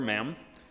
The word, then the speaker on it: Ma'am: Quote, on presentation to the king or queen, the correct formal address is Your Majesty, and subsequently Sir or Ma'am, pronounced with a short A, as in jam.